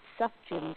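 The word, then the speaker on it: Sufjan: Hey, this is Tiffany, and I just wanted to let you know that it's Suff Jim Stevens, and that's how you pronounce it.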